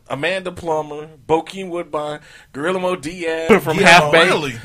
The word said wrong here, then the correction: Guillermo: Guillermo for people that can pronounce it.